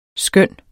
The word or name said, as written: skønne